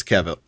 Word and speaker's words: Cavill: Did I say that right? Cavill or Cavill? – It, it's Cavill. Uh, Cavill. I Cavill. think is what it is.